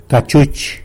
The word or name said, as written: cachuch